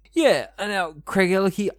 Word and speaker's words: Craigellachie: Craigellachie.